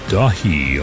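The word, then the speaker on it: Daithí: new album from Neil Young called Dahi, although it's spelled D-A-I-T-H-I. I'm pretty sure it's in Gaelic.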